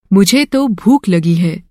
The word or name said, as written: भूख